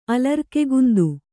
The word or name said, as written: ಅಲರ್ಕೆಗುಂದು